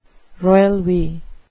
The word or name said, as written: royal we